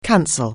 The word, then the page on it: cancel: anglais